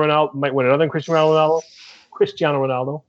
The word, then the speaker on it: Cristiano Ronaldo: I better get that pronunciation right. Easier than Chalhanolo, let's say this. Maybe not as easy for the ESPN guys, right, Richard? – No, they're going to have fun with that. Gosh, I mean, if it's anything like the friendly against Tottenham, that's going to be... Chalalulu was one. Chanalulu. I was waiting for Chugga Choo Choo at some point. It was just butchered just so badly.